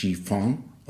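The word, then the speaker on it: Chifon (吃饭): Chifa kommt vom Chinesischen und es wird eigentlich Chifon ausgesprochen und das bedeutet Reis essen.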